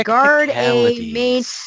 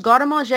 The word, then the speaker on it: Garde à Manger: And they're like, Garde à Main. I'm like, no, it's pronounced Garde à Manger.